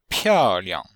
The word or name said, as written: piao4liang5